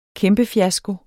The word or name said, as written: kæmpefiasko